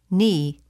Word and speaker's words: knee: The letter K is not pronounced in the following words. Repeat them during the pauses. – No. New. Knowledge. knee, knife, blackguard.